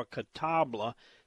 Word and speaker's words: catalpa: Are you saying catalpa, C-A-T-A-L-P-A, or catabla, C-A-T-A-B-L-A? The actual word, the correct spelling is C-A-T-A-L-P-A, which would be pronounced probably catalpa. But, you know, country boys like Jeff, it's catalpa, catalpa worms. And they actually spell it C-A-W-A-T-A-B.